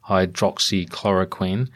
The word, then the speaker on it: hydroxychloroquine: And Kelly, of course, has been a big fan of hydroxychloroquine, if that is in fact how you say it. – That's how you say it. Yeah.